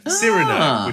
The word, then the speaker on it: Cyrano: Mine is, I picked this also because it wasn't talked about Cyrano. Cyrano, which is how they pronounce it in the film.